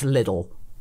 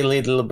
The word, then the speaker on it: Lidl: I know it's some people say Lidl. A lot of my family call it Lidl. That's all. And it's we say Lidl, but it's because how you know you pronounce it in Polish,